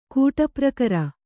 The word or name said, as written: ಕೂಟಪ್ರಕರ